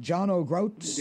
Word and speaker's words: John O'Groats: It's about 80 miles south of John O'Groats, the northernmost town of mainland Scotland. Did I pronounce that right? John O'Groats? – You did. Oh. You did a wonderful job.